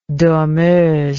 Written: dormeuse

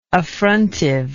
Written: affrontive

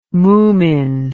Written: mumin

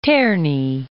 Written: Terni

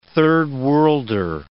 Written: third worlder

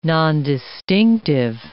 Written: nondistinctive

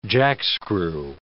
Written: jackscrew

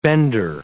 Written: bender

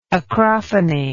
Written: acrophony

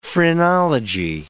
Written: phrenology

